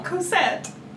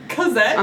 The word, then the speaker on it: Cosette: It sounds like you're saying it with a K. – I'm going to hurt you. How do you say it? – Cosette. – I said Cosette. – No, you said Cosette.